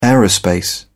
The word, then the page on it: aerospace: English; British English